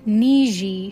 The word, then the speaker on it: Niji: Here is the pronunciation. I'll tell you. Niji, she says, your Niji, which means friend in Ojibwa. Niji.